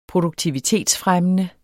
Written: produktivitetsfremmende

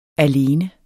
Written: alene

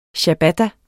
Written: Ciabatta

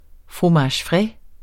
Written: fromage

